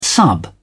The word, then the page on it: sub: British English